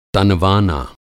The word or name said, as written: तनवाना